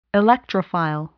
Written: electrophile

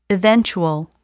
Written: eventual

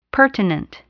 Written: pertinent

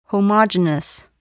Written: homogenous

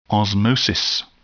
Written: osmosis

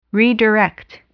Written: redirect

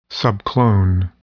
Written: subclone

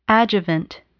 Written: adjuvant

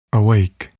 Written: awake

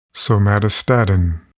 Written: somatostatin

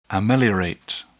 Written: ameliorate

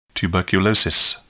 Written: tuberculosis

